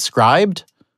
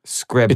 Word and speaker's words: Scribd: How you pronounce it? Scribd? It's not scribed. – Scribd.